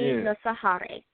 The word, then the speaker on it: Nosakhare: The K at the middle is pronounced in silent, so it's Nosakhare. – That's why you ask people how to pronounce their name. Anthony Nosakhare.